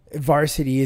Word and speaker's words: VRSTY: But if you're into Issues, Crown the Empire, While She Sleeps, Varsity is the band that you need to listen to. And it's spelled V-R-S-T-Y, all in caps.